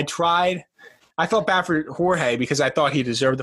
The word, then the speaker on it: Jorge: Some people call him George, other people call him Jorge. Anyway. – Actually, the correct pronunciation is Jorge, and if you don't get it right, you're canceled.